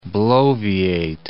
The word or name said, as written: bloviate